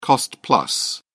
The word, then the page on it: cost-plus: British English